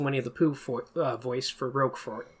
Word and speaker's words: Roquefort: But it's French. They don't pronounce the T at the end. It's just got to be Roquefort. Nah, whatever. Roquefort. And they just don't pronounce it. You're saying it with a hard T. It's like Roquefort, not Roquefort.